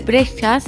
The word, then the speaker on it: Brejcha: Se escribe Boris, así como suena, y Breja se escribe B-R-E-J-C-H-A. – Eh, si me seguís hablando así, te garcho.